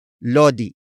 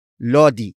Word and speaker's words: lodi: First, we have lodi, lodi, lodi. Lodi is spelled as L-O-D-I.